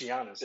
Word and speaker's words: Giannis: It's Giannis. It's just like a zero. – It's a silent G, I think.